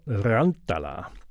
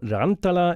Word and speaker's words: Rantala: Und auf dem Song gleich, den wir einspielen, hört ihr auch Iro Rantala. – Das klingt, wenn ich es nicht zu westfälisch ausgesprochen habe, nicht Spanisch, sondern Finnisch. Und